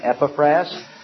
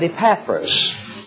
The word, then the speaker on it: Epaphras: Some might pronounce his name Epaphras, but Epaphras was one who was saved, and quite evidently he was one of the key founders of this local church there.